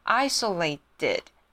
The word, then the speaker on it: isolated: 第二个呢是 isolated，你放在句子里面的时候就把它念成 isolated，把 t 念成 d 的音 isolated。